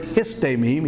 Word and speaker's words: histemi: So instead of I, it would be H-I-S-T-E-M-I. Long E is pronounced A, so it's histemi.